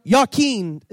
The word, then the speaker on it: Jachin: He set up the right pillar and called the name thereof Jachin, or Jachin is a better way to pronounce that.